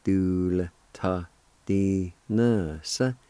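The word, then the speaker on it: dulitadinvsv: Same thing in this word. The I with the L will be silent. And you can pronounce the L sound with the D-U like this. Dool, ta, di, na, sa.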